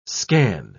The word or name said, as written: scan